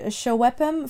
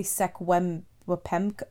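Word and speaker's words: Secwepemc: So, Shuwepem is spelled S-E-C-W-E-P-E-M-C. I apologize if I misspelled it. I Googled up the phonetic. – Yeah. – Because I didn't want to just say Sekwem-wepem or whatever, because that doesn't sound right.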